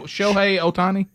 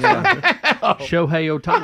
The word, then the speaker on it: Shohei Ohtani: Shohei Ohtani? – Yeah. – Did I say it right? – Yeah, you did. You're dead on it. Well, Shohei Ohtani.